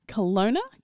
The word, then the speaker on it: Kelowna: I've gone for a band from Kelowna, British Columbia, Canada. I really mucked that up. Kelowna? In British Columbia. – Okay. That's right.